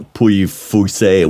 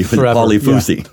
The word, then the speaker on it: Pouilly-Fuissé: Just like we butchered you know, Pouilly-Fuissé, we Polyfusi.